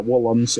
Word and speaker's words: Woolams: Yeah, Woolams. It's spelled W-O-O. L-A-M-S. It's a great last name, to be honest.